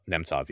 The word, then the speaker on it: Nemtsov: Boris Nemtsov? – Nemtsov, yeah.